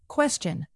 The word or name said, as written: question